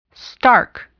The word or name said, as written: stark